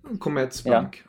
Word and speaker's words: Commerzbank: Hur uttalar man den? Commerzbank Arena? – Commerzbank. Ja. Commerzbank, precis. Så det var bra. Helt rätt.